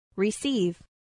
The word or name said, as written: receive